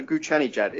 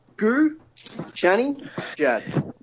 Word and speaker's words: Ghoochannejad: It's there's a Ghoochannejad. It's literally, you know, it's just how it's spelt. Ghoochannejad. Not hard.